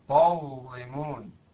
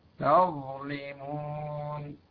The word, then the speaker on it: تظلمون: أيضا لو جاء بعدها ظاء تظلمون، لا تقول تظلمون، تاء وليست طاء، تظلمون.